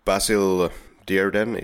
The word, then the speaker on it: Basil Dearden: Yeah, we will most definitely get back to Freddie Jones later in the scene by scene, but writers of this film, Basil Dearden, is how you pronounce the director's name,